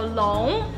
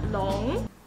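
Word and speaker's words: Long (龙): So Long is the pronunciation of dragon in Chinese. Long is a dragon.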